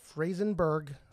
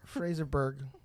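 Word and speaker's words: Fraserburgh: Frazerburg.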